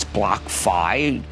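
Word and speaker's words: BlockFi: By the way, I'm told that it's Block Fi. I pronounce Block Fi. Block Fi, like financial. – Like Semper Fi. – Like Semper Fi. No, like SoFi.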